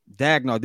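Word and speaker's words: Daigneault: Dagno.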